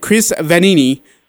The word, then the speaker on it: Vannini: Uh, it's uh, from the Athletic, Chris Vannini. I'm going to butcher that name too, and I just probably did, Chris, and I'm really, really sorry, man. Please forgive me. – No, you got it right.